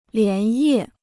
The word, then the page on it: 连夜: lián yè